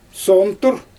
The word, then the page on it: soomtur: Xyzyl